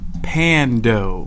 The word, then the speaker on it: Pando: Pando. – Pando. – It's with a pan with the dough, okay.